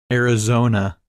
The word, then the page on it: Arizona: English